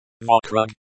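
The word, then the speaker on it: вокруг: Vakruk. – Vakrug, the stress is on the second syllable.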